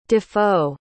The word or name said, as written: defoe